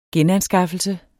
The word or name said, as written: genanskaffelse